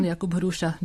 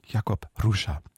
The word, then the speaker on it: Jakub Hruša: Pan Jakub Hruša, dobrý den. – Dobrý den. – Dobrý den. Jakub Hruša heißt er, der Dirigent.